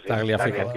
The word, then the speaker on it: Tagliafico: ¿Cómo se dice, cómo se pronuncia? Tagliafico, otro argentino. – Tagliafico, sí.